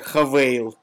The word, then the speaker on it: Haval: H-A-V-A-L. Вот она пишется как «Хавал», «Хавал», но почему-то она произносится как «Хавейл».